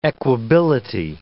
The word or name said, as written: equability